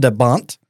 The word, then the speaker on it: de Bont: The film was directed by Jan de Bont.